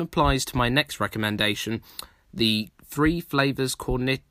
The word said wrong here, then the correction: Cornetto: Cornetto trilogy, say that properly,